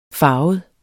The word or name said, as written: farvet